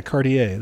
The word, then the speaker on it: Cartier: Yeah, Cartier.